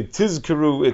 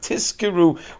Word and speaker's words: tizkeru: We have to make sure that when we say tizkeru, it's with a zayin, and not tizkeru with a sin.